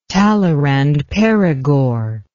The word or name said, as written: talleyrand perigord